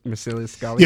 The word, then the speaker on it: Marcellus Gallio: Marcellus Gallio? – Yeah, no, he's not a real historical figure. He was created for the movie, but you are pronouncing it the way they pronounced it in the movie.